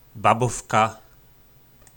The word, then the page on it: Babovka: po nasimu